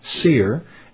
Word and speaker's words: Scere: And it's pronounced Seer. S-C-E-R-E.